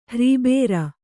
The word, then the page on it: ಹ್ರೀಬೇರ: hrībēra